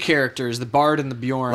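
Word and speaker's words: Beorn: You just didn't pronounce bear right. – Is that Bjorn? – Bjorn. That's right.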